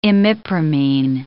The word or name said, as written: imipramine